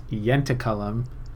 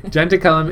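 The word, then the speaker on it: jentaculum: And the last one is, it's spelled genticulum, but I'm pretty sure it's pronounced yenticulum, which would give you a hint of what language it is. It's Latin.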